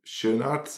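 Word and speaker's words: Schoenaerts: And you have Matthias Schön, how do you say his name? – Schönart? I'm Schönart, I think.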